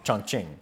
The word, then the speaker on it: Chongqing: Chongqing.